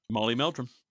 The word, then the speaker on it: Molly Meldrum: Yes, Molly Meldrum. – She has a good rapport with because she has done a few interviews with him before. – Well, it's basically because of Molly Meldrum that Madonna broke in Australia, and she broke in Australia before anywhere else in the world.